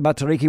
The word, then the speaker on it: Matariki: Okay, you'll be happy about the Matariki, won't you? – I just heard your story and I just wanted to commend you on your pronunciation.